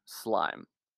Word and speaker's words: SLM: And most importantly, I learned SLM, pronounced slime, the source code tracking tool, like GitHub much later.